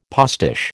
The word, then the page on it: postiche: American English